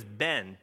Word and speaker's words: Ben: It happens a lot with my own name, which is unfortunate, because my name is Ben, B-E-N, but I don't say it that way. I usually say Ben, like with an I and then an extra E in there somewhere.